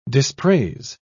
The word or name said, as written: dispraise